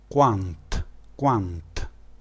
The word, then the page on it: quant: dialetto